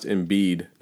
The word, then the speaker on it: Embiid: I think it's pronounced Embiid, not to correct Embiid. You, but yeah, it's Embiid.